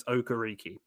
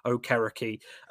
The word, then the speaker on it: Okereke: And then you get guys like, you know, decent role players like Bobby Okereke in – I'm not saying his name how the announcers say it. It's Okereke. Pat McAfee pronounced it correctly. It's not Okereke.